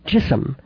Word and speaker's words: Jism: The title of the film is the word for the human body in Urdu, Jism. That's J-I-S-M. It's pronounced a little differently. It doesn't rhyme with prism, more like kissum, and no, the word does not have that other meaning in Urdu or Hindi.